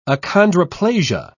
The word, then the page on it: achondroplasia: British English